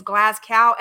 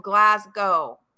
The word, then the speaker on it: Glasgow: And if this castle is in Scotland outside of Glasgow, and it's actually Glasgow, what everybody's pronouncing is Glasgow, everybody stop it. It's called Glasgow. Okay. Glasgow. That's the way it's pronounced. You don't pronounce it as a cow.